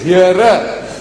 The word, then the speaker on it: Here: This here is not here, is hearer. You pronounce hearer means gentleman.